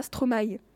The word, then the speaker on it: Stromae: D'ailleurs, oui, avant, avant je le prononçais Stromae, comme beaucoup de monde. Mais du coup, comme j'ai regardé plein de, plein de vidéos aussi pour me documenter, et lui prononce ça Stromae. Ah oui Du coup, ben je, le prononce euh, à la, je le prononce à la Stromae.